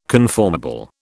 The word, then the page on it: conformable: British English